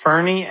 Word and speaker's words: Furney: What's your Eric, you spell it E-R-I-C, right? – Correct. – And what's your last name? – Fernie, F-U-R-N-E-Y. – Okay. And then Derek, his is D-E-R-K.